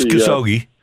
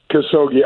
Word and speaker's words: Khashoggi: I mean, what Greg Norman said about the and I'm going to mispronounce it. It's Khashoggi. – Khashoggi.